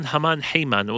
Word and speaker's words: Haman: This is Pizmon with Haman Heyman, or in English often people pronounce his name Heyman, so Heyman makes a bit more sense.